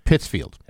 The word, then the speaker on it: Pittsfield: She was released on $40 bail and is scheduled to appear in Pittsfield District Court on Tuesday. – I believe it's pronounced Pittsfield.